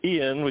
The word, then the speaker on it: Iain: My first name is Ian, which is spelled I-A-I-N from the Gaelic Scottish roots.